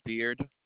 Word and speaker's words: Beard: My last name is Beard.